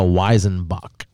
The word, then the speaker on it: Weizenbock: A Weizenbock. I believe that's how you say it, a Weizenbock.